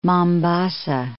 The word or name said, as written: Mombasa